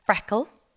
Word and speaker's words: FRKL: It's called Freckle. It's spelled F-R-K-L. We just dropped the vowels because that's what the cool kids are doing.